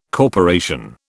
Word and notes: corporation: British English

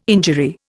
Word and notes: injury: Indian

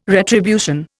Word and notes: retribution: Indian

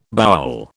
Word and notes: bowel: British English

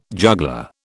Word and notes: juggler: British English

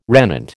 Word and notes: regnant: American English

